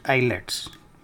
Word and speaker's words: IELTS: because the prospective student has to pass an exam called IELTS, pronounced ILETS, with at least a minimum score.